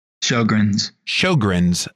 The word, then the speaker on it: Sjögren's: Sjögren's, how do you say that word? – Sjögren's syndrome.